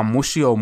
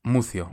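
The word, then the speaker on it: Muzio: Το ζήτα διαβάζεται φύτα. – Μούθιο.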